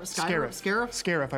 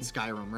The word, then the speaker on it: Scarif: Scarif? Scarif, I believe, is the pronunciation. – I said Skyrim, right? – Skyrim.